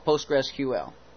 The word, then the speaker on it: PostgreSQL: The MySQL and PostgreSQL. PostgreSQL. That's how they pronounce it.